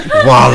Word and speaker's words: Voila: Today we're talking from Viola Montessori. Thanks for joining us. – Thank you. It's actually Voila Montessori. – Voila.